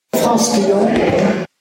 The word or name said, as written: Franskillon